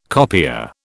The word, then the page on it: copier: British English